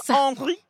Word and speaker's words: Henri: He is French. So it is a French name, and you need to pronounce it Frenchly. – Henri Matisse. – Henri.